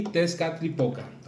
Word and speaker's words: Tezcatlipoca: Tezcatlipoca.